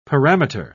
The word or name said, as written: parameter